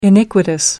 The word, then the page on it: iniquitous: American English